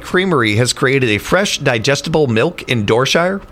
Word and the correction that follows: Dorchester: I mean, if we're in England, sure, guess, it'd be Dorshire, but no, it's Dorchester.